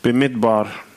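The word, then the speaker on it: Bemidbar (במדבר): Bemidbar. – Bemidbar.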